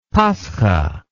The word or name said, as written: paskha